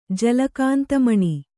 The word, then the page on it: ಜಲಕಾಂತಮಣಿ: Kannada